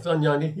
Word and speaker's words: Dohnanyi: Now, the book is about the Germans who resisted Hitler, but it focuses mostly on two people, Dietrich Bonhoeffer and, am I saying this right? Hans von Danagy? – That's close enough. Danagy, yeah.